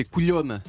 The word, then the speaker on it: colhona: Je crois qu'on me l'a montré, c'est Coulhone, c'est ça ? – C-O-L-H-O-N-A. – Ah, Coulhone. Voilà, ben c'est ça. Mais Coulhone. – Coulhone.